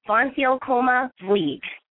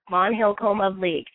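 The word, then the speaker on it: van Hylckama Vliet: It's Pam Von Hillcoma Vliet. That's how you say her name, kids. Von Hillcoma Vliet.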